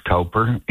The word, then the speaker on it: Cowper: There was a man by the name of William Cowper, sometimes pronounced Cowper in England.